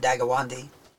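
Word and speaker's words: Deganawida: there's several different ways of pronouncing it, I always pronounce it Dagawandi, who was an Indian.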